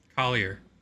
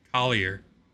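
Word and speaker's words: Collyer: Collier. – What? – Collier. – Collier.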